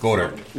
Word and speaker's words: Goedert: Dallas Goedert. Goedert. – Goedert.